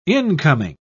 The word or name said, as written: incoming